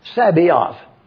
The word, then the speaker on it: Sabaoth: S-A-B-A-O-T-H. I'm saying it slowly and deliberately for you to see it. Sabaoth. Some pronounce it Sabaoth. And they put the S-A and then the rest of the word. Let's stay with Sabaoth. S-A-B-A-O-T-H.